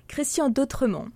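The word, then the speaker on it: Christian Dotremont: Logogramme by Christian D'Autremont. – There you go. Perfectly said. Wow, just great pronunciation there.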